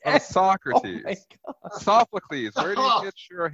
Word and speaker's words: Sophocles: Sophocles. Okay. Hey, guys. Sophocles. Come on. – Sophocles.